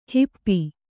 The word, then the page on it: ಹಪ್ಪು: happu